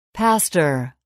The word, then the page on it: pastor: [pǽstər]